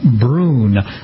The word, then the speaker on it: Brunn: Let's talk about some of the highlights of the auction, beginning with a 1939 Packard 12 Touring Cabriolet by Brunn. Is that the correct pronunciation, B-R-U-N-N? – Yes, it is. I think they say Brunn is the proper terminology for that.